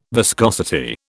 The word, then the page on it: viscosity: British English